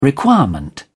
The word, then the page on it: requirement: British English